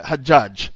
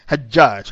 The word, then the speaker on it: Hajjaj: And people prayed, people used to pray, Hajjaj, H-A-J-J-H. It is spelled like Hajjaj, not G, Hajjaj.